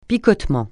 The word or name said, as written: picotement